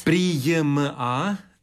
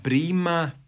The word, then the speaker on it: prijma: Prijma, nema prima, prijemu. Znam da je to teško izgovoriti, da mnogi su zbog toga zbunjeni. Naime, kad je riječ o ovom obliku prijam, onda je to nepostojano A kao glasovna promjena, što je dobro da se podsjete naravno maturanti, i onda se to A gubi u svim padežima osim u genitivu množine još.